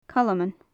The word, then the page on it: calman: gaelic